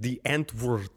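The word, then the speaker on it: Die Antwoord: Our band is called The Antwoord. And that means the answer. The people from America, they say it a little bit funny because they can't say it right. How do they say it? They say... – And they say like, The Antwoord. It sounds a bit... fucking stupid, actually.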